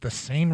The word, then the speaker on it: Seine: The perpetrators parked the basket lift on the left side of the Louvre that faces the, is it the Seine River? How do you say that? Seine? – The Seine, yeah.